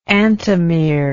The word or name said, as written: antimere